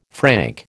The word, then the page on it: franc: American English